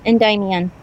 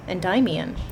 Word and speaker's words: Endymion: I know there's one that you probably haven't read, knowing you, but took a lot of inspiration from Greek myth, specifically with Endymion, if that's how you pronounce it. – Endymion. – Endymion. Okay. – At least that's how I pronounce it, but we really can't know how the Greeks would have pronounced their own names, so it doesn't matter.